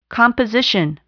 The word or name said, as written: composition